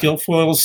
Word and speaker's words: Guilfoyle: And it was right in the middle of Kimberly Guilfoyle's, is that how you pronounce her name? – I believe so, yeah. – Guilfoyle's speech, which she was just yelling off the top of her lungs.